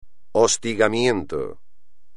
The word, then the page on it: hostigamiento: espagnol